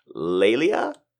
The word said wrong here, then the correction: Lila: Hi. It's Lila. – Lila.